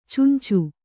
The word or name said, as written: ಚುಂಚು